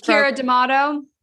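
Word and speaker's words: Keira D'Amato: So I hope I don't get her name wrong, but Kira DeMatteo, is that how you say her name? – Oh, she just Kira D'Amato. – Kira D'Amato. – I think it's D'Amato, I think, but I'm not positive either. – That sounds right.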